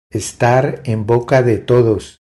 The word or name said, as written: Estar en boca de todos